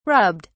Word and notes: rubbed: British English

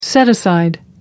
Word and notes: set-aside: American English

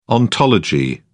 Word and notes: ontology: British English

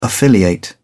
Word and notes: affiliate: British English

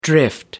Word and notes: drift: American English

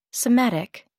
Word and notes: somatic: American English

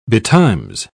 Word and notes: betimes: British English

